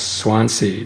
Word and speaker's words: Swansea: Swansea.